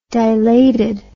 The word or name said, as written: dilated